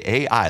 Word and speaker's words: Ai: Okay, Ai, literally is how it's said.